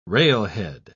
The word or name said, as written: railhead